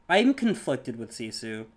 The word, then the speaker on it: Sisu: Is it Sisu? – Yeah, Sisu the dragon.